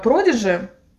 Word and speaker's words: Prodigy: Я еще увидела э, Продижи, Firestarter, вот этот клип. Просто он так и произносится.